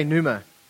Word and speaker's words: Pneuma: Last Sunday, we started our new series called Pneuma. Everybody say Pneuma. Yes, you got it, but it's with a P. So drop the P, all right? It's a silent P.